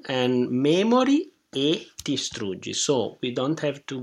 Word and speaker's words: distruggi: That is very important because we have STR, mm-hmm. three consonants together. Mm-hmm. So we have to pronounce precisely three consonants. Str. It's very important to have the S in the upbeat. – That it really starts early. So we're cheating the T. – Yeah, exactly. Tistr. Memorie distruggi. And also I suggest to add the S to the syllable before. Che memorie distruggi.